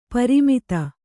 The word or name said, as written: ಪರಿಮಿತ